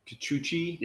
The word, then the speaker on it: Kikuchi: I can't pronounce his name. – Kikuchi.